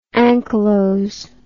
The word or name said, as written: anchylose